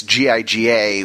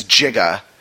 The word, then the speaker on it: giga: heard a scientist pronounce the metric prefix GIGA, which we now say as giga, as in gigabyte, as jiga, you know.